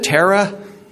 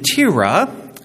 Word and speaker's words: Terah: And so if you're thinking, isn't it Terah? Or are you sure it's Arpachshad? Yeah, according to the Bible pronunciation app, I got these. Shem, that's easy, and Terah, the generations of.